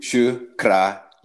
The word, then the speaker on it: Shukraya: Yeah. Shukraya. I always feel like I put the emphasis on the wrong syllable. – Shukraye. – Shukraye.